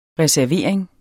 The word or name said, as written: reservering